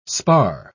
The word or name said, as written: spar